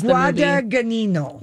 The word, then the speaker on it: Guadagnino: I just know that's it's Guadagnino.